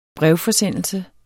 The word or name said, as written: brevforsendelse